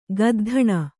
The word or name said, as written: ಗದ್ಧಣ